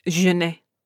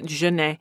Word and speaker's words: Jeanette: Um, my middle name is Jeanne. It's spelled Jeanette, but everyone pronounces it Jeanne.